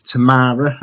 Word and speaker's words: Tamara: Her name is Tamara.